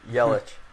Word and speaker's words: Yelich: So, uh, kind of to attribute to all this success, um, it all starts with Ulich, uh, soon to be NL MVP. Did I say that wrong? – Yelich. – all right, yeah, Christian Yelich. Nice.